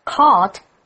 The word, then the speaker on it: caught: 对耶，所以通常是去搭飞机搭火车，就是那个不等你的交通工具的时候，你会用这个字。所以我们常常会有说去赶公车赶火车的意思，用 catch 这个动词，注意它的三态哦， catch, caught, caught, C-A-U-G-H-T-G-H 不发音， catch, caught, caught.